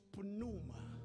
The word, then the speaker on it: pneuma: The word for spirit is pneuma.